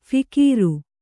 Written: ಫಿಕೀರು

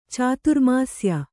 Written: ಚಾತುರ್ಮಾಸ್ಯ

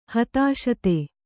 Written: ಹತಾಶತೆ

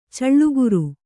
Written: ಛಲಗಾರ

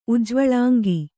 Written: ಉಜ್ವಳಾಂಗಿ

